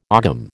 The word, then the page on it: ogam: American English